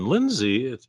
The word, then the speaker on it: Linzy: And to let everyone know, it's spelled S-H-A-W-N-A, Shawna. And Linzy, it's a little bit different. It's L-I-N-Z-Y.